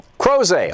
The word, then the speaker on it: Crozet: In Crozet, Virginia. Am I saying that right? – Crozet.